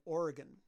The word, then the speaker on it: Oregon: Now, one of the things I know is you grew up, and I can say it right, in Oregon. – Yes. – Not Oregon.